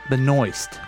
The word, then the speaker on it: Benoist: The actress's name that is Supergirl is Melissa Benoist. – Come again? – That's her name. – Okay. Benoist? – I think so. – Okay. – B-E-N-O-I-S-T. – That sounds like it. Interesting. Just rhymes with moist.